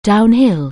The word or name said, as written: downhill